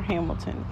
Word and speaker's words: Hamilton: And it's going to be MELAAINE underscore Hamilton.